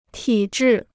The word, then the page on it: 体质: tǐ zhì